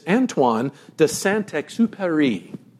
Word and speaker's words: Antoine de Saint-Exupéry: It is Antoine de Saint-Exupéry.